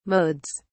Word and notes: modes: British English

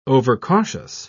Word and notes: overcautious: British English

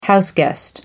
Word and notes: houseguest: American English

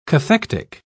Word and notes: cathectic: British English

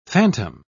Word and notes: fantom: British English

